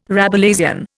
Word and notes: Rabelaisian: Indian

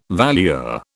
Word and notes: valuer: British English

